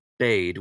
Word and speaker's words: bade: and I did it in British dialects and I used a slightly more old-fashioned pronunciation of the word bade. We would say he bade him come to the hall, and I said I pronounced it bad because in that style that is what they used to say, and that is how some people still say it, especially the classical actors and so forth, and it was suitable to that setting, but someone jumped on me for pronouncing it that way.